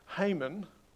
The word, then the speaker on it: Haman: He has a name that can be said a couple of different ways. His name is spelt H-A-M-A-N. So we have Haman, the English sort of version of it, and then we have Haman, which is the original old Hebrew way of saying it. I'm going to stick with the English pronunciation for today.